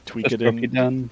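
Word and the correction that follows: Toukiden: Tokiden, yeah. – Tokiden.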